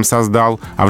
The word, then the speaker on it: создала: То же самое создал, лучше, чем создал, а в женском роде создала.